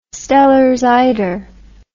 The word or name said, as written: Steller's eider